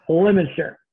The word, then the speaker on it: Leominster: Oh, Leominster. Yeah. – Leominster.